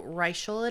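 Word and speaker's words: Reichl: Is it Reichel? – That's the way she pronounced it in the audiobook. – Then that's what we're going to go with, because in my head it was Reichel. – Oh. – So Reichel it is.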